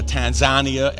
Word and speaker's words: Tanzania: They're spending some time now in Tanzania, or Tanzania as some would pronounce it, on their way back to Uganda.